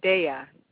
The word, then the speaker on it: Daya: It's Navasha Daya, D-A-Y-A, so the day and then I don't know why people struggle with that, because it's day.